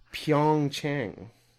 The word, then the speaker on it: Pyeongchang: It was Pyeongchang.